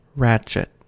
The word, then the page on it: ratchet: American English